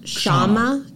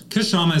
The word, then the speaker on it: Kshama: So that's pronounced Kshama. Kshama. – Kshama is how you probably say it in America. In India, you say it all at once.